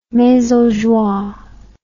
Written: maison joie

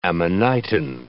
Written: amanitin

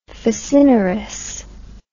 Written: facinorous

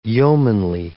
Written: yeomanly

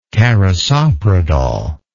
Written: carisoprodol

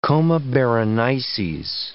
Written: Coma Berenices

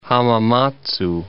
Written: Hamamatsu

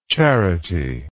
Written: charityless

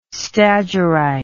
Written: stagirite

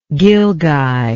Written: gilguy

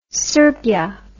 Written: srbija

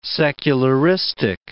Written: secularistic

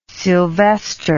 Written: sylvester

